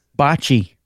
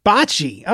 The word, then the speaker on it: bocce: How do you pronounce that word? – Bocce. – Bocce. Okay. – Bocce. Yeah. B-O-C-C-I.